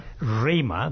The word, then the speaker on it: Rima: Rima, because it rhymes with my wife's name, Prema.